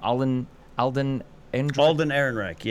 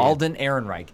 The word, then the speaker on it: Alden Ehrenreich: Alden Ehrenreich. Yeah. – Alden Ehrenreich. I think he was fantastic. I really do. – I have been burned at the stake for this. I think Alden Ehrenreich was a better Han Solo than Harrison Ford in Return of the Jedi.